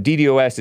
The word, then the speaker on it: DDoS: It's either, but DDoS is the more common pronunciation, says Asmodor.